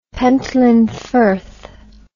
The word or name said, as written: pentland firth